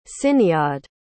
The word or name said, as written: ciniod